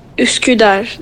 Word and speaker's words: Üsküdar: Üsküdar. That's how you pronounce Üsküdar.